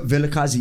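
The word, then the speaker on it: Vilakazi: I hope I'm pronouncing it right. Villa Casey Street in Soweto was the home of two Nobel Prize winners. – Yeah, Villa Casey, yeah.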